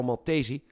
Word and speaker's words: Maltesi: Nieuw nieuws komt vandaag met gruwelijke details over de moord op de Italiaans-Nederlandse pornoster Carol Maltesi.